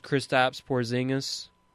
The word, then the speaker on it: Kristaps Porzingis: The last big to win was Kristaps Porzingis. Unless I'm pronouncing his name wrong, Charlie. – No, you got it.